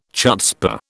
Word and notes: chutzpah: British English

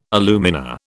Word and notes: alumina: British English

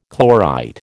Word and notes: chlorite: American English

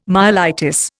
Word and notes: myelitis: Indian

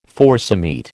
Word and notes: forcemeat: American English

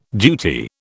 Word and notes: duty: British English

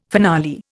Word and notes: finale: Indian

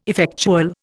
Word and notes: effectual: Indian

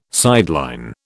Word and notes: sideline: British English

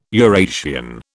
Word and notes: Eurasian: British English

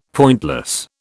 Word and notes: pointless: British English

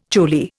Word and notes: choli: Indian

